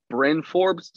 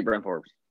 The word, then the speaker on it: Bryn Forbes: Brian Forbes versus Landry Shamet. – I'm gonna need you to say his name right. His name is Bryn Forbes, sir. – Bryn. – I'll take Bryn Forbes.